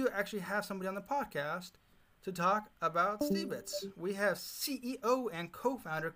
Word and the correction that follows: Stibits: the way to pronounce it is actually uh, Stibits. Uh, so S T I B I T S. It's a platform. Yeah, Stibits.